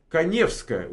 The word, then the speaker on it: Каневская: Каневская, ударение на Е.